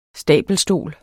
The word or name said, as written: stabelstol